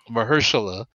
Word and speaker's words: Mahershala: Mahershala.